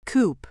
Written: coupe

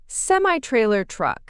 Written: semi-trailer truck

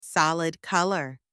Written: solid colour

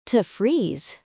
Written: to freeze

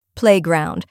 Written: playground

